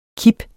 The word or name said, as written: kip